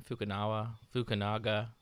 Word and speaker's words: Fukunaga: Fukunaga, who did the Fukunaga, first season of True Detective, and he's the first American director to do a Bond movie.